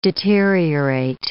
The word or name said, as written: deteriorate